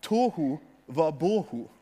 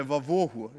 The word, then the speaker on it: tohu vabohu: På hebraisk så er det Tohu vabohu, eller vavohu, litt etter hvordan du uttaler det.